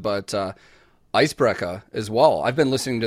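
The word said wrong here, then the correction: Eisbrecher: It's actually not, if you want to pronounce it correctly, it's Icebreaker. It's the sh. – Okay. – The ch can be two things. It can be a ch and it can be a sh.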